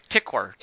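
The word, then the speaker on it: Tykwer: How do you say his name? – Right, Tickver.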